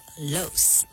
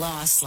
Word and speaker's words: Los Angeles: Jamie Lee pronounces it Los, like rhymes with gross. Mariska is team Los, like boss.